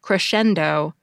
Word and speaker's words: crescendo: It's pronounced like shen, crescendo, but it's spelled S-C-E-N, which, to me at least, looks like it should be pronounced sen.